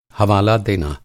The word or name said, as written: हवाला